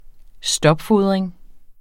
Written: stopfodring

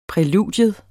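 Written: PRÆLUDIERNES

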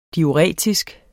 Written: diuretisk

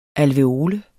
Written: alveole

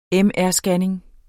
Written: MR-scanning

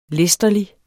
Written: læsterlig

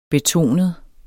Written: betonet

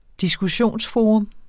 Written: diskussionsforum

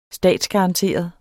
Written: statsgaranteret

